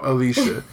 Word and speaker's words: Alicia: Is her first name pronounced Alicia? Her name is Alicia. It's spelled Alicia. Ain't nobody about to pronounce it Alicia. Alicia Silverstone, right? – You just pronounced it Alicia.